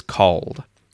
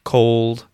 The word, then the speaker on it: cold: So, for called, the vowel sound is the long or vowel sound, called, called, called. And for cold, it's the short oh vowel sound, like in hot, cold, cold, called, cold, called, cold, cold.